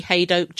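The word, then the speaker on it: Hadoke: Rhymes with joke. Haydoke. That's how I remember it.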